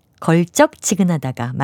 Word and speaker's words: 걸쩍지근하다: 걸쩍지근하다 첫음절을 된소리로 발음하는 것을 많이 들어보셨을 텐데요. 맞는 것은 예사소리로 쓰고 그렇게 발음하는 걸쩍지근하다가 맞는 표현입니다.